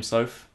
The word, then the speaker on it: Soffe: It's William Soaf. You spell it S-O-F-F-E.